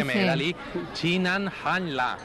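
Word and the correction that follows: Xin nian kuai le (新年快乐): Xin nian kuai le.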